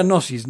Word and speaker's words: gnosis: o sea, se pronuncia gnosis, ¿no? Pero para marcarle la G, que es como esta idea eh, del verbo.